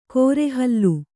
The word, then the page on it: ಕೋರೆಹಲ್ಲು: Kannada